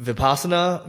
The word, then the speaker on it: Vipassana: And also, as we work towards wrapping, a couple minutes left, how do you pronounce it? Is it Vipassana meditation? Mm-hmm. Is that what it is? – Yeah.